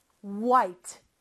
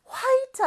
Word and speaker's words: white: white. white 아니고요. white.